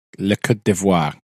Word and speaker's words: Côte d'Ivoire: Officially, it's the Côte d'Ivoire. That is the official pronunciation of that.